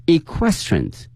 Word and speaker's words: equestrian: 那么这个词很多同学见到之后呢不认识，那么今天我们学一下这个词叫 equestrians。